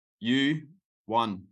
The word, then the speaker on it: Johan: It's pronounced you won, like the trophy, like you won.